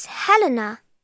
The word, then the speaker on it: Helena: It's Helena. – How do you spell that? – H-E-L-E-N-A. – H-E-L-E-N-A.